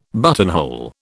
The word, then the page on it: buttonhole: British English